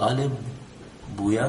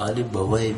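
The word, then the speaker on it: آل بویہ: اس کا تلفظ بعض لوگ آل بویا کرتے ہیں اور بعض لوگ آل بوائے بھی کرتے ہیں, تو کبھی آپ نے سنا ہو تو کنفیوز نہ ہو کہ کیا ہوا ہے.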